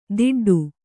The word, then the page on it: ದಿಡ್ಡು: diḍḍu